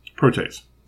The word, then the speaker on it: protease: I think I'm reading that word a little too phonetically. – Mm-hmm. – Is it just protease? – Protease.